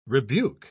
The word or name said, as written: rebuke